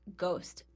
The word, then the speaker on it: ghost: Ghost. So, the word ghost, the H is just a little ghost waving at you. – Yeah. – Because it's a silent H and it's a secret ghost.